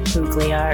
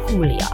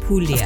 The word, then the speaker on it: Puglia: I said like Puglia or something really sad. And it's like Puglia. – Puglia. – Of course, you wouldn't really pronounce the G. I should know better.